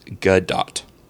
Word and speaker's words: Gadot: The correct pronunciation of her name is Gadot. G-U-H.